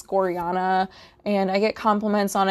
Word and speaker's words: Gorjana: It is spelled G O R J A N A, and the J is pronounced like a Y, so Gorjana.